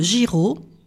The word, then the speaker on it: Giraud: En juillet 1943, Marie-Louise Giraud, G-I-R-A-U-D, comme ça se prononce, mère de famille de 40 ans, est guillotinée pour avoir pratiqué des avortements illégaux.